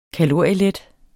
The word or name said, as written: kalorielet